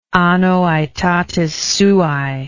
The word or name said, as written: anno aetatis suae